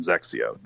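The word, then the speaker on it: XXIO: It's like it sounds kind of like Sexio, but with a Z instead of an S, like a Zexio.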